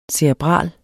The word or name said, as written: cerebral